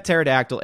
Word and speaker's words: Pterodactyl: Hardwick says, if you had a pet pterodactyl egg, what would be a correct name to give it? Hint, there's only one correct answer and it starts with a silent P. Pterodactyl?